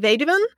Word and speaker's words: Weduwen: So my Twitter is at DJ Vedewen, which is spelled WEDUWEN.